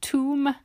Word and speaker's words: tomb: Do notice the silent B in tomb, because we've been talking a lot about silent letters on Instagram recently.